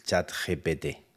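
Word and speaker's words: ChatGPT: Eh, ahora está muy de moda el chat GPT.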